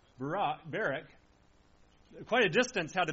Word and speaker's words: Barak: Here Deborah issues a call to a man named Barak, or Barak, however you want to pronounce it, but Barak is how I'll try to pronounce it here.